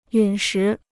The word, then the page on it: 陨石: yǔn shí